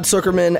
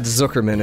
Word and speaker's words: Sucherman: And I guess the way that he explained it is Zook like book. So Todd Sucherman, if you were ever wondering how to pronounce that name.